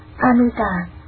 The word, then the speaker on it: Amita: Chữ A Di Đà nguyên tiếng Ấn Độ đọc là Amita,